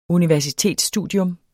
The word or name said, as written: universitetsstudium